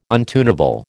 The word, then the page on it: untunable: American English